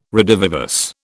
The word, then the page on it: redivivus: British English